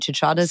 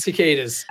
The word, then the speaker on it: cicadas: And then what I loved in your book on bug noise, you speak about the 17-year cycle of these, how do you say it, chuchadas? – Cicadas.